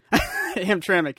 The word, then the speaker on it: Hamtramck: Hamtramck, yeah.